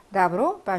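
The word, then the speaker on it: 나브로 (добро): 나브로, 바샬라바지. 자, 그래서 얘가 오가 들어가고요. 나브로, 강세가 없어서 얘가 아발음 나는 거지 쓸 때는 오라고 쓰셔야 됩니다.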